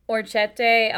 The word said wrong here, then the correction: orecchiette: al funghi. Fungi. – Orecchiette. Orecchiette. So orecchiette al fungi.